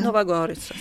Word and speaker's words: Nuova Gorizia: E quest'anno Gorizia e Nuova Gorizia, ho detto bene Nuova Gorizia? – Nuova Gorizia, sì.